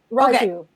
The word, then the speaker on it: Raju: Raghu actually gets him as he's dangling. – Is it Raghu, not Raju? It's spelled Raju. – Raju. Okay. It should be Raju.